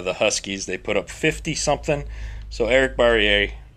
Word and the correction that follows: Barriere: Barriere. I'm going to pronounce everyone's name wrong. I'll just a fair warning. Hate me on Twitter if you want. Eastern Washington's QB. – Okay. Brian. – It's Barriere, but for my quarterback, I'm going to take Case Cookus from Northern Arizona.